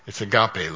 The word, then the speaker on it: agape: It doesn't say God knows love, and when it says God is love, it's agape love. It's an E. A long E, agape love.